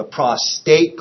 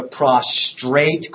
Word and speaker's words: prostate: The next accessory sex gland that the man will talk about is called the prostate gland. Please do not call it the prostrate gland. That is incorrect. It means something totally different in English. You could look it up, but please say it correctly or they'll wonder where you took your course.